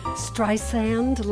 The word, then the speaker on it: Streisand: Barbara Streisand. – Streisand? Like sand on the beach. Soft S.